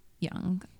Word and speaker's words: Duong: It's spelled D-U-O-N-G, mm-hmm. but it's pronounced Young.